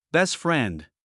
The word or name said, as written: best friend